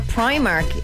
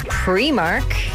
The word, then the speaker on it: Primark: I learned that it's not Primark, it's actually pronounced Primark.